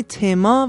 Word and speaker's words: تم: ت مثل تم، کلمه تم با معنی لغوی موضوع فرانسویه و در ایتالیایی و آلمانی تما و در انگلیسی تیم تلفظ میشه.